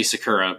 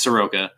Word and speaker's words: Soroka: Yeah, I was going to say Sakura, but it's Soroka.